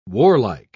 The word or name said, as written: warlike